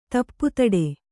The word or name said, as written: ತಪ್ಪುತಡೆ